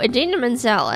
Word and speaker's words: Idina Menzel: Anyway, so Idina Menzel, as her name is appropriately pronounced, she is, she's most famous for singing two really big, loud, famous songs.